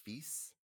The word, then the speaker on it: Fils: F I L S, like Mon Fils, but just no Mo.